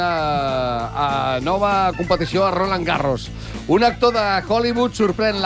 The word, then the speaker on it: Garros: Però es pronuncia Garros.